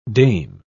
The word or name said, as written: dame